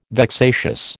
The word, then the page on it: vexatious: American English